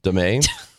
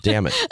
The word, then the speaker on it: Damet: He's talking about the new head coach of City SC, Yoan DeMay. And Harambe says, I believe it's pronounced damn it. It isn't. It's spelled D-A-M-E-T.